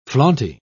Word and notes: flaunty: British English

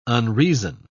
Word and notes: unreason: British English

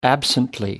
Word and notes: absently: American English

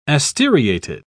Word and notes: asteriated: British English

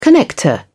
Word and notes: connector: British English

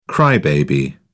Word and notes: cry baby: British English

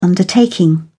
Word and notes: undertaking: British English

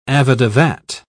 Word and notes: avadavat: British English

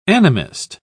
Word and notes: animist: British English

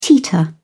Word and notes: teeter: British English

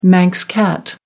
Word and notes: manx cat: American English

